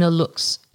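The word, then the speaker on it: aluxe: How do you say this word? because I don't speak Spanish. A-L-U-X-E. A lux.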